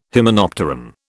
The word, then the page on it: hymenopteran: British English